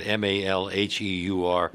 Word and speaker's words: Malheur: Did the people who occupied the Malheur, I forgot how to pronounce that, Malheur, yeah, Wildlife, right, Refuge headquarters in 2016, consider themselves sagebrush rebels? – Well, frankly, you know, that's a lunatic fringe.